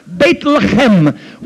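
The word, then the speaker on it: Beit Lechem (בית לחם): Bethlehem in the Hebrew language is pronounced Beit Lechem, which means house of bread.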